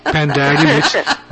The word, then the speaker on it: Pandowdy: I'm sure it did, and we're not going to have Anne sing a book about Pandowdy, which rhymes with howdy.